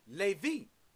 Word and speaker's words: Levi: In the African Hebrew, you pronounce it Levi, 3878.